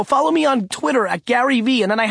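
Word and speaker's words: GaryVee: Gary V with two silent E's.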